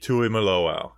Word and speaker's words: Tuimoloau: All right, let's finish off way too early draft week with JT Tuimoloau, and I learned how to pronounce that name. It's like, suck it.